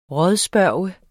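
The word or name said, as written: RÅDSPURGT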